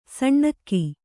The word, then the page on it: ಸೀನ್ಕೆ: kannada